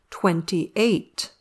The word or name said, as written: twenty-eight